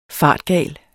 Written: fartgal

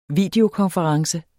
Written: videokonference

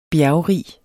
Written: bjergrig

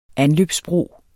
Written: anløbsbro

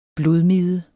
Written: blodmide

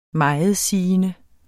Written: megetsigende

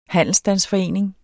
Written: handelsstandsforening

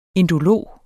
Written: indolog

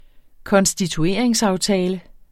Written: konstitueringsaftale

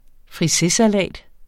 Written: FRISESALAT

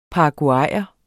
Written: paraguayer

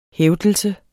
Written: hævdelse

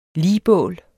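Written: ligbål

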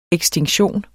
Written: ekstinktion

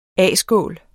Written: A-skål

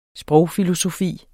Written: sprogfilosofi

